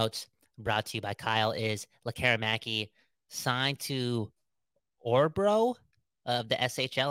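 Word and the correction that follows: Örebro: Am I saying that right? Begsy? – I think it's a Rebro. – Oh, a Rebro.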